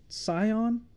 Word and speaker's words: Scion: I was going to say, and I don't know exactly how to pronounce, is it Scion? – Yeah.